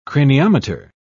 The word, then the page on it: craniometer: British English